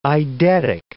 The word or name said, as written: eidetic